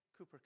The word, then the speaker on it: Cowper: Cowper, depending, I think it's pronounced Cooper, but it's, for us Canadians, it looks like Cowper, C-O-W-P-E-R.